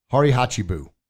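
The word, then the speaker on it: hara hachi bu: And this is, and I got my paper here, so I say it right. Harihachibu. Yeah, I said it. Yes, it's a Japanese word, harihachibu, which means essentially eat until you're 80% full.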